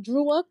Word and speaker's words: Dreux: Yes, but the way it's spelled looks like it's saying Drewux because he's French. It's like spelled like Dreux or something like that, but it's pronounced Drew.